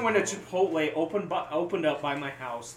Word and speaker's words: Chipotle: No one can pronounce the E. It's Hispanic, Dad. Chipotle.